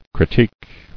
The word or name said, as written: Critique